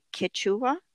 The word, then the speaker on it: Quechua: Her name comes from the, let me see if I say it right, Quechua. – Yes. – Does that sound right?